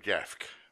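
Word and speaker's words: Jaffke: Produced by, I like this bit, Ty West, Jacob Jafk, J-A-F-F-K-E, I think that's the right pronunciation.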